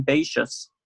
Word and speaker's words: Boethius: Boethius, or some people pronounce him Boethius, was the first Christian scholastic.